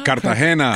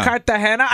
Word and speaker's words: Cartagena: She said Cartagena? – Cartagena. – Cartagena.